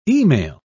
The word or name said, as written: email